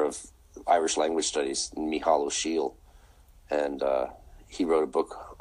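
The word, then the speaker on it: Ó Siadhail: It's Michal O'Shiel. And O'Shiel is spelled O-S-I-A-D-H. – You'll have to A-I-L, of course.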